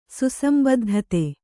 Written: ಸುಸಂಬದ್ಧತೆ

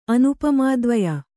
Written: ಅನುಪಮಾದ್ವಯ

